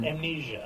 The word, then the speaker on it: Amnesia: i probably am pronouncing oh, it wrong uh, uh, uh Am, am, amnesia. Amnesia. Yeah, sorry.